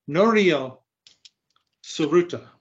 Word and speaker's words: Norio Tsuruta: I do think Norio Tsuruta, I do think that is the correct pronunciation of the gentleman's actual name.